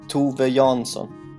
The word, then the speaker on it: Tove Jansson: Do I pronounce her name correctly? Uh, well, kind of, yeah. uh, so what's her name? Sorry, would you would you like to say yeah, it? In Swedish, her name. pronounce it uh, Tove Jansson, and mm-hmm. in English, I guess Tove Jansson.